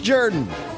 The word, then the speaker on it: Jordan: It's pronounced Jordan.